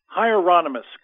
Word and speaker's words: Hieronymus: I want to say Hieronymus, but I'm not sure uh, uh, that's how you say it. – Hieronymus, correct.